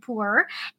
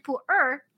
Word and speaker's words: Pu'erh: So I visited Yunnan before in China, and I've visited Pu'erh, and Pu'erh, just kind of like the numbers 1, 2, 3 in Chinese, yi, er, san. So that sound is Pu'erh that you would pronounce, but in Cantonese, which I'm actually from Hong Kong, so I speak Cantonese, it's bo lei. Totally different sound.